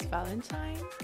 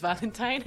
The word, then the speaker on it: Valentine: My name is Valentine and I'm French. I'm 28. So actually my name is Valentine, but no one can pronounce this. So my name is Valentine, I guess.